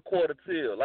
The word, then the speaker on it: Quata Till: Um, it's pronounced quarter till, like quarter till, like seven forty five.